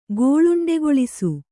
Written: ಗೋಳುಂಡೆಗೊಳಿಸು

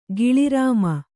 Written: ಗಿಳಿರಾಮ

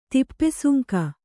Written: ತಿಪ್ಪೆಸುಂಕ

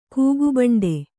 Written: ಕೂಗುಬಂಡೆ

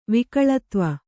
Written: ವಿಕಳತ್ವ

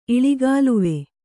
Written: ಇಳಿಗಾಲುವೆ

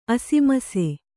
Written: ಅಸಮಿಸಿ

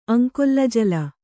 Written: ಅಂಕೊಲ್ಲಜಲ

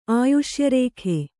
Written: ಆಯುಷ್ಯರೇಖೆ

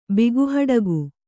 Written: ಬಿಗುಹಡಗು

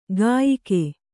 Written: ಕಯಿಕೆ

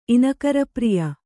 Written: ಇನಕರಪ್ರಿಯ

